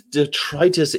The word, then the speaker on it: detritus: If you use detritus in speech, remember to stress the second syllable as you do with the word arthritis and bronchitis.